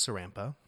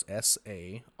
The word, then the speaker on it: Saranpaa: So my last name is Saranpaa. – It's a very Finnish. – And let's spell that for everybody. – There's a lot of A's. So it's Saranpaa.